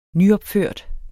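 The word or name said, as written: nyopførte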